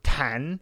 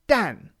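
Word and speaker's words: Dan (弹): So when this character is pronounced Dan, it means bomb or bullet, you know, really bomb. And so a bullet is a zi dan, which means a child bomb. So if you can see how bomb is the better word for it, and then that's why zi dan for bullet means child bomb. Now, tan is the other pronunciation, and this is the more obvious from the way the character looks.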